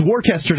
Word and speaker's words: Worcester: It's Worcester to anybody who can speak English. And we speak English just fine up here, thanks. – Worcester, I would think.